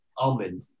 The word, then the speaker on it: almond: Do you say the almond. L or do you miss the L? Almond or almond? – I'm an almond guy. – Yeah, there almond guy. You're a big almond guy. – Well, I'm gonna have to beep that out on the edit. Thank you very much. – I know. – No, cheers. – Just coming in with a slander. – The L is silent.